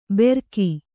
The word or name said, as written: ಬೆರಕಿ